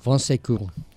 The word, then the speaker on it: Bon Secours: Bon Secours.